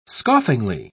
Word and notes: scoffingly: British English

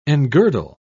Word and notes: engirdle: British English

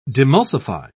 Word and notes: demulsify: British English

